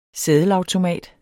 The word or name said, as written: seddelautomat